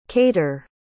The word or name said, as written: caitir